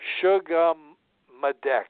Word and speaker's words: Sugammadex: Sugammadex, S-U-G-A-M-M-A-D-E-X.